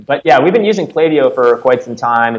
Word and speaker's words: Klaviyo: I go, okay, tell me once and for all, how do you pronounce it? And it's Klaviyo.